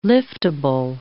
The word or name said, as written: liftable